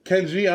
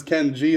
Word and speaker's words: Kenyi: K-E-N-Y-I. Spelled wrong, too. – K-E-N-Y-I. – Kenyi. – Yeah, but it's Ken G is how my parents have been calling me.